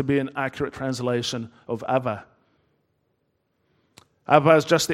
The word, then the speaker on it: Abba: And often in that language, a B is pronounced almost like a V. So, Abba, Abba, like that.